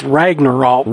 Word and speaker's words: Ragnarok: It's not an Alabama Ragnarok. You pronounced it wrong. It's Ragnarok.